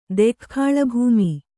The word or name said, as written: ದೆಖ್ಖಾಳಭೂಮಿ